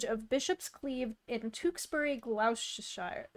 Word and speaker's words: Gloucestershire: No, it is not Gloucestershire. It may look like it, but it is to be said as Gloucestershire.